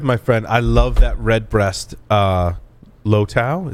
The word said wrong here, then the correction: Lustau: Is that how you say it? Lustau.